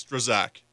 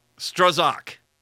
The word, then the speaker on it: Strzok: Strzok. Is that the official Strzok? – According to producer Hunter, it's Strzok. – Strzok. Producer Hunter.